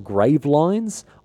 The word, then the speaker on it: Gravelines: The English then press the advantage and attack, and so began the enormously important Battle of Gravelines almost certainly wasn't the right way to say it. It's spelt grave lines. I did look up a pronunciation guide. I've already forgotten how to say it. Gravelin. I'm going to go with Gravelin, and then at least I have paid lip service to the, the correct French pronunciation.